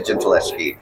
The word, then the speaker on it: Gentileschi: through there, and it was a painting, a self-portrait by Artemisia Gentileschi. I think I'm saying that right. – Gentileschi. New name on me. – Yes, all right. Artemisia Gentileschi.